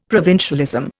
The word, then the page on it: provincialism: Indian